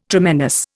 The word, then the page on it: tremendous: Indian